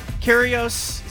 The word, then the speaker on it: Kyrgios: It normally doesn't, but I was kind of into the kind of the drama, and you had obviously uh, Kyrgios.